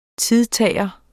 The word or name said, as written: TIDTAGERE